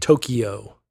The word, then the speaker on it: Tokyo: Tokyo drives me up the wall. Just like, say it like it's two syllables. Tokyo. Kyo. That's the, I don't know how to say that. Well, it's like Q, but instead of Q, you say Kyo.